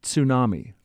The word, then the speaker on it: tsunami: And the proper pronunciation, tsunami. Thank you for that.